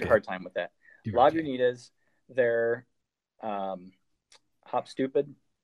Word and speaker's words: Lagunitas: This was so other drain pours like Lagunitas. Did I say that right? – You did.